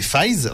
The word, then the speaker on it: Faiz: Donc comme tu disais, donc il y a Fosé, il y a Faiz, donc ils écrivent 555 mais c'est Faiz. Donc je vais prendre dans l'ordre hein, je vais commencer par Faiz.